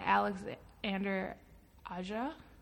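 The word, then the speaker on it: Alexandre Aja: And it's actually considered, well, it was directed by Alexander Aja. – Yeah. – That's how it's pronounced.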